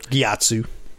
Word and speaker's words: Gyatso: Gyatso. – Gyatso.